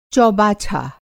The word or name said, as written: चौबाछा